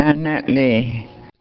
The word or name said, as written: laanaa lee